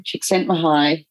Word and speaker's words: Csikszentmihalyi: and so am I going to pronounce his name? Csikszentmihalyi. – That's it.